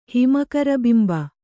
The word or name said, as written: ಹಿಮಕರಬಿಂಬ